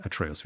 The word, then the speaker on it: Atreus: Ja, mittlerweile auch Atreus oder Atre Atre Atreus. Atreus wird da ausgesprochen, genau.